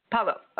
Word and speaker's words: Paolo: So, you know, talk a little bit about Nat and I'm not sure, how do you pronounce his name, Paolo.